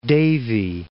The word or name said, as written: Davie